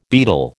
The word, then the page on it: beadle: American English